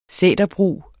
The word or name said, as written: sæterbrug